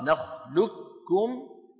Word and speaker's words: نخلقكم: بإدغام القاف في الكاف إدغاما محضا. كتبنا في السطر الأخير وحفص يقرأها نخلقكم بالإدغام الكامل.